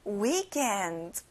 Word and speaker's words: weekend: 주말, weekend 앞에 we에 강세를 두는 거예요.